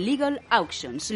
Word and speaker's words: Legal Auctions: Legal Auctions. ¿Lo he dicho bien? Lo has dicho perfectamente incluso mejor que yo lo pronuncio.